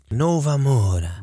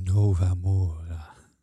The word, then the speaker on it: Nova Mora: Eh, de partij heet Nova Mora. Nee, je moet het anders uitspreken. Nova Mora.